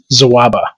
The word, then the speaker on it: Sawaba: And so my Twitter handle is just @SAWABA, which I pronounce Zawaba with a Z sound.